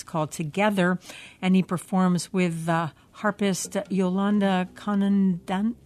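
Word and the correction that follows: Kondonassis: Condonassus. – Condonassus.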